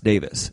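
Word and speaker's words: Davies: And then they played a clip of him being on the air saying it's pronounced Davis.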